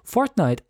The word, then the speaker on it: Fortnite: Fortnite, ein.